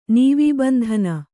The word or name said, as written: ನೀವೀಬಂಧನ